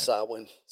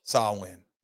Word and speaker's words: Samhain: It's Samhain. – Samhain. You don't pronounce that the same way we do English.